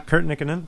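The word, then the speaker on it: Kurt Nikkanen: Yeah, Kurt Nickinen. – Yeah, in Finland they always pronounce the first syllables, stress the first syllable in every name, in case you're wondering.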